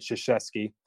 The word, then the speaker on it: Krzyzewski: That's how you say it, Krzyzewski.